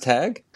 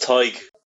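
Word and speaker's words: Tadhg: And the Australian media had a field day with his name, Tag, which is it's T-A-D-H-G. Is that pronounced Tag? – It's pronounced, over here, it's pronounced Tig. – I've got one name right and one name wrong in the first 3 minutes. – I can completely understand confusion with Tig. Like, as a kid, the first time I saw it, I was a similar way, but it's just think like Tiger without the er part. That's how you pronounce it.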